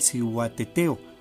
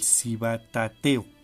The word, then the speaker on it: Cihuateteo: Esta es una leyenda que viene de los antiguos aztecas, que se llama el Cihuateteo. Algunos le llaman el Cibatateo, pero más o menos es la pronunciación.